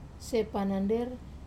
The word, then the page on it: panander: wamesa